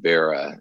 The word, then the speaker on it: bara: It's really spelled B-A-R-A. – Okay. – But people pronounce it Barah, and so we put the A-H on so they'd pronounce it right.